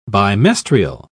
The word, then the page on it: bimestrial: British English